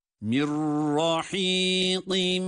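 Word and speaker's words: Mirrahiqin (من رحيق): Cezimli nundan sonra ra harfi gelmiştir. Burada idgamı bilahun ne vardır? Ra şeddeli gibi okunur. Mirrahiqin. Evet. Mirrahiqin mehtum.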